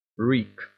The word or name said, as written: reek